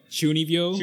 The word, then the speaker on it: Chunibyo: Uh, Ryan's a real life Chunibyo. Is that how you say it? – Chunibyo? – No, Chunibyo. Chunibyo.